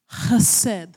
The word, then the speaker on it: Chesed: Chesed. – There you go. That's the word mercy. Isn't that great? So good. Chesed is 149 times translated into mercy in English.